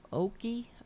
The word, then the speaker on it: Ocoee: How do you pronounce it? Oki Massacre? More than 50 African Americans killed. Oki, O-C-O-E-E Massacre, after going to vote.